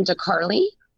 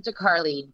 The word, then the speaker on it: DeCarli: My name is Erin DeCarli. – And Erin is spelled very unique, A-I-R-E-N. – Correct. And my last name is DeCarli, D as in dog, E-C-A-R-L-I.